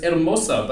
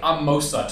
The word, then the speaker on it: Hermosa: No, I think that he got given a bunch of words and they were like, look, the H is silent on this one. And he went, yeah. – I've got it, the H is silent. – Well, it probably is. It's Hermosa, but he says like Amosa to rhyme with Samosa.